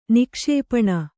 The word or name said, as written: ನಿಕ್ಷೇಪಣ